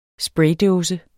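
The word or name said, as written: spraydåse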